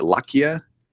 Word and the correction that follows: Leica: Leica. – Leica? Is it just Leica?